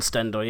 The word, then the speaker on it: Stendel: Stendel, I believe is how you pronounce it. – Yes, Daniel Stendel, yeah.